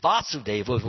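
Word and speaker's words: Vāsudeva: And Vasudeva, with the long A on the second letter.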